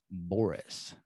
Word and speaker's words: Borras: How do you say your last name? – Borras.